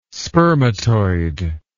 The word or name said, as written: spermatoid